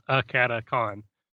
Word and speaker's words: AcadeCon: Yeah, it's right off the bat, it's a terrible name because no one pronounces it correctly. Everybody thinks it's AcadeCon. – AcadeCon. – Yeah. It's supposed to be a portmanteau of Academy, which is the RPG Academy, and Convention. So it's AcadeCon.